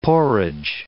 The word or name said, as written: porridge